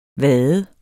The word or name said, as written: vad